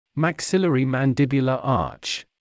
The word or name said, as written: maxillary/mandibular arch